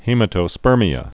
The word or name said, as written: hematospermia